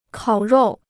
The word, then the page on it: 烤肉: kǎo ròu